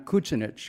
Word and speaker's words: Kucinich: My grandfather, John Kucinich, because that's how they pronounce the name in Croatia, came from an area called Eastern Slavonia.